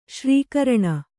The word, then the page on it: ಸರಕರಣ: sarakaraṇa